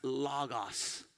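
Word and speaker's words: logos (λόγος): Now that word in Greek is pronounced logos.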